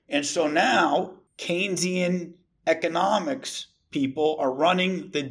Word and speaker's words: Keynesian: And I always say this guy's name wrong, and I've never – Keynesian economics. How do you say this guy's name? – Yeah. It's Keynesian. John Maynard Keynes and Keynesian.